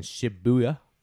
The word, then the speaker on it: Shibuya: These people wake up in Tokyo, which is where they're from, obviously, in Shibuya. That's how you say it.